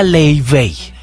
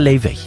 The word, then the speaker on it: Laufey: Y su nombre se escribe Laufey, Laufey. – Sí. – Y el billboard que ha llamado más la atención de todos los observadores culturales que van a Coachella y todo, ese es el letrero que dice: se pronuncia Lay Bay.